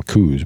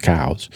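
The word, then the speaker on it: Coues: Everyone calls them coos, but technically it's pronounced cows.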